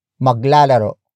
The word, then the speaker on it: maglalaro: Maglalaro. Maglalaro. Maglalaro. Maglalaro is spelled as M-A-G-L-A-L-A-R-O.